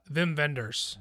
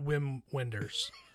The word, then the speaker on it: Wim Wenders: It's Velm, not Whelm. It's Velm, right? – Vim Vendors. – Vim Vendors. – Vim Vendors, yeah. – You have to say it right. – Not Wim Wenders.